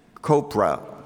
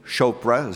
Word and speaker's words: Chopra: And so you have leaders of a movement like that, like Deepak Chopra. I think I mispronounced that, actually. Chopra, is that better?